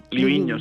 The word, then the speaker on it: Livigno: Livigno, sí, sí, sí.